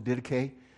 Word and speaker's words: Didache: It's called the Didache, at least that's how I pronounce it. It's the way I've heard it pronounced.